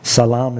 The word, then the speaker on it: salam: Salam is how you say it in Arabic.